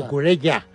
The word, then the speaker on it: Cucurella: Bueno, Cucurella.